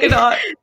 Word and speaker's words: Gadot: Gal Gadot. – This is what I'm talking about. Suddenly you get rich and famous and you don't pronounce a T in your name.